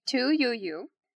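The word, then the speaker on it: Tu Youyou: And then her last name is Y-O-U. Y-O-U. And I did look up how to pronounce it, and that was what kept showing. So I'm gonna roll with it.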